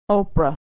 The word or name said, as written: Oprah